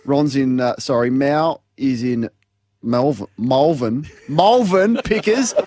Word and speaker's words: Malvern: Let's go to Mal in Malvern. I think that's how you say it. Malvern. Malvern. That's how you say it properly. – You got it right.